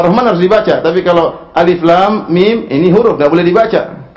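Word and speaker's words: Alif, lam, mim (الم): Oleh karenanya to, sin, mim tidak boleh dibaca, tapi dibacanya per huruf. To, sin, mim. Alif, lam, mim. Jangan dibaca alam, keliru.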